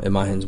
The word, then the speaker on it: Imagen: I'm going to say it looks like Imagine, but it's not spelled Imagine. – It's pronounced Imagen.